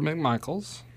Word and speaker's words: McMichael: Deborah McMichaels. – Deborah. I think it's just McMichael. They always got their name wrong, too. There's no S on it, I don't think.